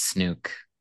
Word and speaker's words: Snook: Is it Snook or Snook? Rhymes with Luke. It's Snook. – It is Snook. – Like in the word zoo, Snook.